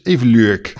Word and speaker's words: Eyjafjallajökull: De Eyjafjallajökull. Weet je hoe dat uitspreekt trouwens? Als je dat opzoekt, dat spreek je uit als Evelurk.